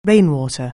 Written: rainwater

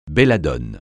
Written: belladone

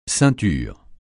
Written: ceinture

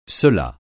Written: cela

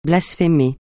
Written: blasphémer

